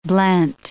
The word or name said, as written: blent